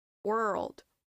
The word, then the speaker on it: world: And I have a trick for you to pronounce this word. Imagine there are two words, we or we are old, world, world, world world, world, world. Now say it faster.